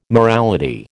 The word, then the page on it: morality: American English